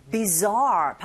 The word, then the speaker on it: bizarre: 전치사 뒤에 bizarre 발음하실 때 이 z 여기에 손을 대시면 마치 벌이 날아갈 때 들리는 소리처럼 zar, zar 그냥 우리말의 지읒 자가 아니라 z 여기서 끌어올려주시는 발음이라고 생각하시면 돼요.